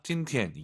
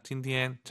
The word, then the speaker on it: 今天: 아아 일성 잘 하셔야 됩니다. 今天 이게 아니라 今天.